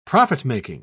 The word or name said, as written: profit-making